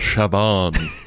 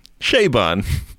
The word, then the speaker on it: Chabon: Chabon? that's like calling it Target. – How do I really pronounce it? – Chabon.